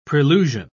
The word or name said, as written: prelusion